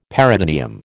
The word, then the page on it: peritoneum: American English